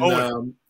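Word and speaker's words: Eoin: Well, his name is spelled E-I-O-N, and... Owen. Yeah, and well, you would rightly recognize that as being Owen, but the majority of the rest of the Earth, it's Ian, it's Owen.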